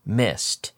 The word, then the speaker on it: missed: First of all, in terms of pronunciation, here we have a strong ST sound on missed, missed. Okay, any regular verb that ends in an S, okay, I'm talking about miss, pass, kiss, for example, all of these verbs in the past are going to sound like an S-T. Kissed, missed, passed.